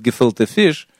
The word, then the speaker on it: gefilte fish: Нет, они празднуют, как надо празднуют, то есть вот они, может быть, не говорят, они, может быть, не готовят gefilte fish. Вот. Кстати, скажите, пожалуйста, gefilte fish. – Gefilte fish. – Gefilte. Слушай, вы правильно говорите.